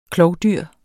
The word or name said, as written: klovdyr